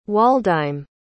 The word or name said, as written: Waldheim